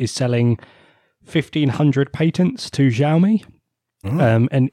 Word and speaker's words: Xiaomi: Xiaomi. – I'm going to say Xiaomi. – Xiaomi.